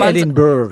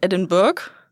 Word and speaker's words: Edinburgh: Also so spricht man es natürlich aus. Edinburgh. – Ja, also nicht, ich bin jetzt auch keine Expertin. Aber nicht Edinburgh. – Genau, man sagt nicht Edinburgh.